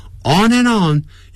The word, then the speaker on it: on and on: On and on 要连接、产生连音，就要一直不断地 on and on，不要念成 on and on。 On and on，那个 D 啊，A-N-D 的 D，不发音了。